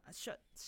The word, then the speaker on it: Shults: Uh, Schultz, Schultz, Schultz, Schultz. It looks like Schultz, but with an S.